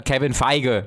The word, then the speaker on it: Kevin Feige: Das war, das ist der Film, wo Kevin Feig, spricht man den glaube ich aus, gesagt hat, Kevin Feige nennen wir ihn hier, mhm.